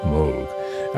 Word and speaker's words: Moog: It actually seemed like a made-up word which people still often mispronounce as Moog. But it was the family name of Robert Moog who created the Moog synthesizer in 1964 and changed the world of music forever.